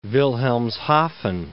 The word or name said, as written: Wilhelmshaven